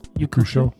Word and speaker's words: Yakusho: Yakusho. – Yakusho? Is that right? Yakusho? – Yakusho. – Yakusho? – Yeah. Japanese names sound are pronounced exactly how they're spelled.